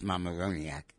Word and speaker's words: Mamaroneck: So Jeff in Mamaroneck, which I pronounce Mamaroneck, wants me to know, to talk about what my predictions are for 22.